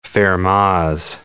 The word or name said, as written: Fermat's last theorem